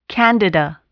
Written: candida

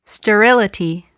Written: sterility

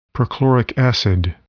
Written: perchloric acid

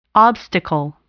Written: obstacle

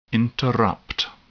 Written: interrupt

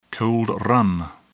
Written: cold run

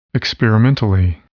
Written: experimentally

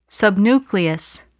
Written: subnucleus

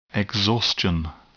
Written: exhaustion

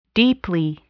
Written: deeply

